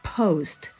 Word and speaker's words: post: 母音になりますので post post というふうに二重母音をはっきりと言いましょうあと最初の P ですねこれも p p っていう破裂音をしっかりと出して post post post post ではなくて post というふうに発音してみてくださいはい。